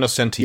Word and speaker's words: Nocenti: Yeah, Anne Nocenti.